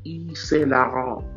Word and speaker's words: Yves Saint Laurent: Yeah, I said it right. Yves Saint Laurent.